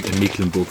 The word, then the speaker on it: Mecklenburg: Das ist äh, ein sogenanntes Dehnungs-C, das sich in, Mecklenburg versteckt.